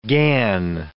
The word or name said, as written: gan